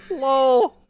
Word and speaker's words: Lowell: Lowell, L-O-W-E-L-L. Lowell. – Oh, okay. – Lowell, Lowell. Is that how I should say it? – Lowell. – Well, I was just listening. – Worcester and Lowell. – Lowell. – Yeah. No, that's how you say it. You just say Lowell.